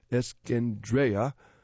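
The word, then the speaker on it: Eskendereya: Given that he will have an extra 220 yards to run at Churchill Downs on May 1st, Escandera, pronounced Escandrea, could win the run for the Roses by an even bigger margin.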